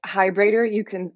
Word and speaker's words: Heidbreder: Heidbreder. This is a rather crass way of saying it, but it's like a vibrator, but with an H.